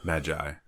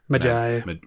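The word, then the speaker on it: Medjay: But it's M-E-D-J-A-Y. – Magi. – Magi. – With a silent D. – All right. – It's a silent D in the middle. – The Magi.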